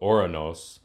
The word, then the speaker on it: Ouranos: He's also called Ouranos, which is the Greek pronunciation, and my knowledge of languages is very limited.